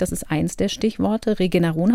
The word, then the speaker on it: Regeneron: Also diese Firma Regeneron oder also amerikanisch Regeneron ausgesprochen. – Ich habe es jetzt der Schreibweise halber, damit man es nachvollziehen kann, deutsch ausgesprochen.